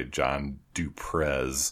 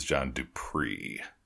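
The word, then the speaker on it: Du Prez: Just last episode, I believe, we were talking about the score by John Duprez. And John Duprez's name, turns out, is John Dupree.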